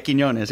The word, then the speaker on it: Quiñones: Yeah, Quiñones, yeah.